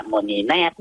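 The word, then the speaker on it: Monineath: ถ้าออกเสียงเป็นภาษาคแมก็คือมณีนาถนะโมนีแนบ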